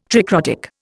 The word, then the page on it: tricrotic: Indian